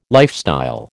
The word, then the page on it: lifestyle: American English